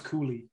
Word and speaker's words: Cooley: It's pronounced Cooley. It's a difficult name. When you look at it, it can be difficult to figure out how to pronounce it.